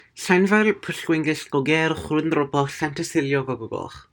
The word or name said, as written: Llanfairpwllgwyngyllgogerychwyrndrobwllllantysiliogogogoch